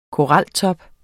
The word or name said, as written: koraltop